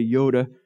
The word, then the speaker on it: iota: If you have your Bible, what you read might be, take that and translate that into the Greek, and it might say iota, which is the way we pronounce it, but the Greek would say yoda.